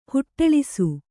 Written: ಹೊಟ್ಟಳಿಸು

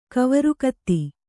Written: ಕವರುಕತ್ತಿ

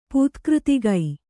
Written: ಪೂತ್ಕøತಿಗೈ

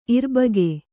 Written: ಇರ್ಬಗೆ

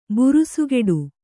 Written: ಬುರುಸುಗಟ್ಟು

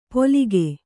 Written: ಪೊಲಿಗೆ

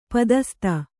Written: ಪದಸ್ತ

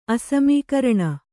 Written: ಅಸಮೀಕರಣ

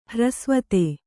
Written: ಹ್ರಸ್ವತೆ

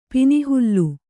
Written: ಪಿನಿಹುಲ್ಲು

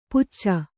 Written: ಪುಚ್ಚ

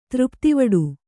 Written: ತೃಪ್ತಿವಡು

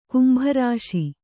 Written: ಕುಂಭರಾಶಿ

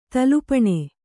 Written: ತುಲಾಪಾನ

